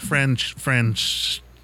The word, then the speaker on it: Francesa: And Mike Francesa is how you say his name.